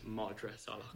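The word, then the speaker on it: Madra Salach: Sorry, it's Madras Salak. – Have I been saying it wrong the entire time? – I don't know. I didn't. That's the first time I've caught it. I was asked to intervene with pronunciation. – You are. I did. I told you. – We haven't made it easy on you with Irish. – Madras Salak. Madras Salak.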